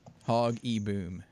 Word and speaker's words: Hoogaboom: I think it was Gary Hoogaboom. – Maybe. Let's see. – Gary Hoogaboom. Hogaboom is how you spell it. Hog-e-boom.